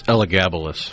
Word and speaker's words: Elagabalus: Elagabalus, is that the name of the emperor? Is that right? Elagabalus. – I think it's Elagabalus. – Elagabalus. Okay. Well, I wasn't sure where to accent the syllables, but... – You put the wrong emphasis on the wrong syllable.